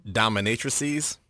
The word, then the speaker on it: dominatrices: where a 37-year-old priest and two dominatrixes, or how is that pronounced? – Dominatrices. – When you're talking about more than one.